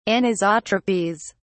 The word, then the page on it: anisotropies: British English